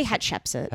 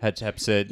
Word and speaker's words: Hatshepsut: How do I pronounce it? – I say Hatshepsut. – Hatshepsut. – Yeah.